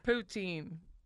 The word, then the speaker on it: poutine: It is poutine. Am I saying it wrong? – I don't know, but if it's routine. – You're saying it right. – Then it would be poutine.